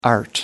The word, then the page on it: art: American English